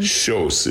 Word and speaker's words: Kjos: Schjos. It's Norwegian. Schjos, K-J-O-S.